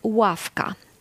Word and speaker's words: ławka: Dzisiaj wymowa się zmieniła już od jakichś mniej więcej prawie 100 lat. Nie jest to uważane za błąd, kiedy powiemy ławka. Wobec tego jest to wymowa podobna do U, tak? Czyli ławka, takie dłuższe U.